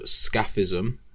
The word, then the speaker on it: scaphism: And the first one we're going to start with is called Scaphism, which is spelled S-C-A-P-H-I-S-M, not F. I know I have a weird accent, so that's how it's pronounced.